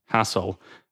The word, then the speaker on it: Hasil: He was the youngest of 10 children, so his name is pronounced Hassel after his brother Basil, which was written Basil but pronounced Basil.